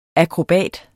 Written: akrobat

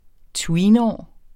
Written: tweenår

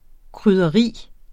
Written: krydderi

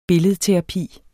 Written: billedterapi

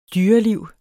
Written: dyreliv

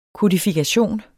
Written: kodifikation

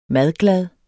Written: MADGLAD

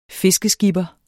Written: fiskeskipper

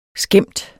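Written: skæmt

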